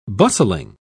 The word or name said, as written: bustling